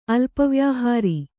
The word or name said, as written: ಅಲ್ಪವ್ಯಾಹಾರಿ